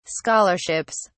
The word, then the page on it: scholarships: British English